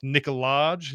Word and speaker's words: Nicolaj: um, and so uh, apparently it's is it pr- pronounced Nicolaj? You have to you do the J and everything. I don't know. – I, I do. Um, uh, yeah, you do the J.